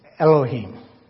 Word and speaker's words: Elohim: So it's Elohim.